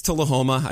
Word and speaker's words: Tullahoma: Correct me, it's Tullahoma.